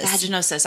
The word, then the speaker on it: vaginosis: Vaginosis.